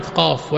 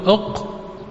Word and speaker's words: قاف: قاف في القرآن مكتوب حرف واحد قاف، ولكن عندما تأتي لتنطقها كيف تنطقها؟ تقول أق، لا لا تقول أق، تقول ماذا؟ قاف.